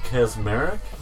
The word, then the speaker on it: Kaczmarek: Jane Kaczmarek? Is that how you say her name? – Yeah.